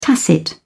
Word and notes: tacit: British English

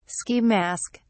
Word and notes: ski mask: British English

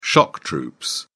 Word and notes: shock troops: British English

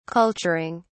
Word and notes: culturing: British English

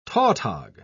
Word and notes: tautog: British English

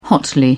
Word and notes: hotly: British English

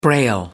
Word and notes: braille: American English

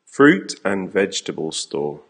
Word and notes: fruit and vegetable store: American English

